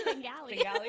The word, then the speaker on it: Bengali: Bengali. I don't even know if I'm saying it right. – No, you did. Bengali? Actually, yeah. You're actually one of the very few people that actually pronounced it correctly.